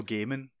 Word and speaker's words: Gaiman: How would you say it, Dom? – Neil Diamond. – Apparently, it's Neil, apparently it's Neil Neil Gaiman.